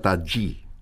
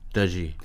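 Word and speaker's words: Tajy: Se escribe Tají con J, Y, pero me han dicho que se pronuncia Tají. – Tají.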